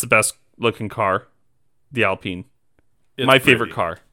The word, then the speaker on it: Alpine: Looking ahead to the other team that's got a new name, and this is how you pronounce it, Alpine. It's not Alpine, which is what it looks like. – It's French.